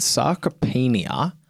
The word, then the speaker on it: sarcopenia: So recent research has found a strong link between diabetes and sarcopenia.